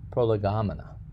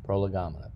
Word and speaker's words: prolegomena: And I think it is pronounced prolegomena, prolegomena, P-R-O-L-E-G-O-M-E-N-A.